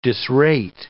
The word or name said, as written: disrate